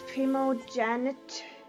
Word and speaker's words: primogeniture: It's primogeniture.